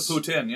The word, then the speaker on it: poutine: Oh, that's the poutine, yeah.